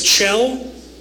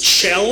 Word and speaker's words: Kjell: My name is Chell. It's Chell. It's Norwegian. It's spelled K-J-E-L-L. Pronounced Chell.